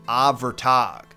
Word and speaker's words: Ábhartag: Prime example, our very first figure, whose name is spelled Ábhartag, but if the pronunciation guides I listen to are to be trusted, is said like Ávartag.